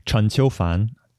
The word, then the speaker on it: Chen Qiufan: That book is Waist Tide by Stanley Chan, or as his name might be pronounced in Chinese, Chen Qiufan.